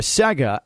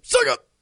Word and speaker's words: Sega: The common pronunciation for every American was Sega, as if it was S-A-Y-G-A-H. Sega.